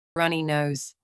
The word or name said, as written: runny nose